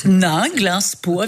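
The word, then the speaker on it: Ninglinspo: C'est écrit comme ça se prononce. – N-I-N. Absolument. – G-L-I-N. – Oui. Nainglinspo, avec O au bout.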